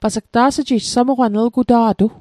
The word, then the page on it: ta's'ji'jg: Mi'gmaq